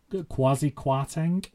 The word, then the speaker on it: Kwasi Kwarteng: Kwarteng, so Kwasi Kwarteng, I believe that's how you pronounce his name.